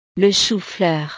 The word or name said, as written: le choufleur